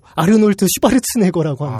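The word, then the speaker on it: Arnold Schwarzenegger: 그리고 또 오스트리아 발음으로 아르놀드 슈바르츠네거라고 합니다. 아, 그래아 정말로 그렇구나.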